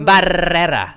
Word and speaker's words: Barrera: That's how you say it, right? – That is exactly how you say it. – Is that really it? – 14 R's so, in that last name. – Barrera and Robert Barbosa. I gotta get good at rolling the R's.